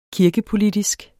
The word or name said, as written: kirkepolitisk